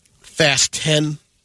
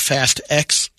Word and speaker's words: Fast X: Some of us have been pronouncing the 10th Fast and Furious title wrong. – Sucky? I'm saying that wrong? It's Fast 10, not Fast X. – Fasten? – And that's the director's pun in a way. Fast 10, your seat belts.